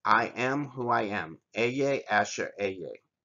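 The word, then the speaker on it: Ehyeh Asher Ehyeh: It's pronounced Eye Asher Eye. And what that term is I am who I am. I am that I am. Eye Asher Eye.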